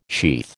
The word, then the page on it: sheath: American English